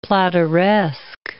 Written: plateresque

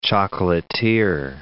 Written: chocolatier

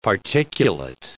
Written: particulate

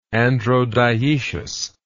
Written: androdioecism

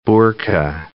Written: burka

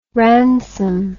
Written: ransome

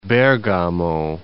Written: Bergamo

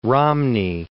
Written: Romney

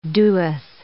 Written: doeth

